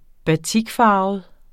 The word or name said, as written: batikfarvet